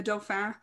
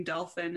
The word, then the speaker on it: Dauphin: And that's also in Henry VI when they deal with Joan and the Dauphin, like right before Joan is taken on the battlefield, they start poking Joan by saying Dolphin instead of Dauphin, or like mispronouncing.